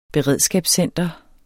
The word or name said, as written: beredskabscenter